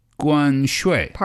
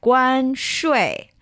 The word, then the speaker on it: 关税: And tariff in Chinese is 关税. – 关税. – Perfect. Again, this is 关, that's the first tone. 税 is the fourth tone. So you're going 5-5-5-3, right? 关税.